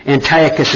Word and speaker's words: Antiochus: Antiochus is spelled A-N-T-I-O-C-H-U-S. A-N-T-I-O-C-H-U-S.